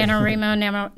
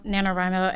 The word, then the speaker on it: NaNoWriMo: NaNoWriMo is how it's pronounced, correct? – There's both ways. Okay. NaNoWriMo, NaNoWriMo, and it's about 50-50 split how you say it.